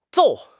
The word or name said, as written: 揍